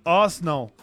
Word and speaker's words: Arsenal: ¿Ustedes sabían que en Inglaterra le dicen en dos sílabas? – ¿Ar? – Arsenal.